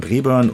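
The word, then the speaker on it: Braeburn: Ja, Breburn ist schon richtig ausgesprochen.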